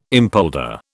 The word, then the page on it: impolder: British English